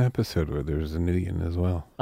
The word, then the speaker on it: Nguyen: It's Nguyen, and it's pronounced Nguyen.